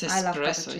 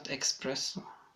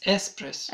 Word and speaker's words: espresso: It's espresso, it's not espresso. – Okay, espresso. – No, it's espresso. – Espresso. Okay. Well, I'm having lessons on how to pronounce coffee. I mean, it's my accent. I have an It's not accent. It's just, there is no K. It's espresso.